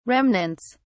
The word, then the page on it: remnants: British English